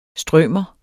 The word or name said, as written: strømer